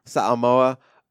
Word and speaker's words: Samoa: Samoa. – What they call Independent Samoa. It's only been like playing football for like 3 years. Played rugby. They don't have high school football in Samoa.